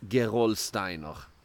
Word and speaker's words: Gerolsteiner: In German, I would call this Gerolsteiner.